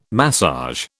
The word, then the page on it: massage: British English